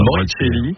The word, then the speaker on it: Morricelli: Morricelli, c'est pas ouais, comment on prononce. C'est bien dit comme ça. – Morricelli.